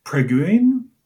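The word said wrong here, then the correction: preboggion: is that correct? – Prebujun. – Prebujun.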